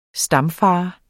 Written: stamfader

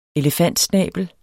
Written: elefantsnabel

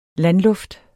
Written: landluft

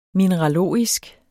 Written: mineralogisk

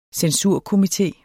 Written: censurkomité

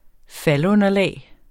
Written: faldunderlag

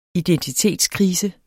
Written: identitetskrise

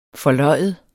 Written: forløjet

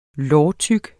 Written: lårtyk

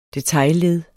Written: detailled

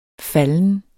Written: falden

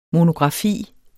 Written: MONOGRAFIEN

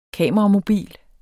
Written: KAMERAMOBIL

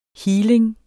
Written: healing

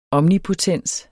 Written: omnipotens

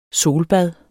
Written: solbad

